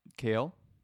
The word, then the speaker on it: kale: So what's funny about it to me is that the A has two syllables. So say that again. – Kale. – Yeah. So ale. – Kale. – Kale. – Kale. – Yeah. It is a one syllable word, actually. Somehow makes it into a two syllable word.